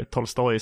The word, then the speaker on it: Tolstoj: Eh, men den heter Anna Karenina av Lev Tolstoj, eller Tolstoj som det egentligen uttalas där på ryska.